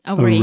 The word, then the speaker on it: array: array, array sorry the stress is on the second syllable